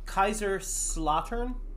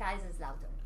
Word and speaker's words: Kaiserslautern: Kaiserslautern? – That's the American way to pronounce it. – Okay. So what is the correct pronunciation? – Kaiserslautern.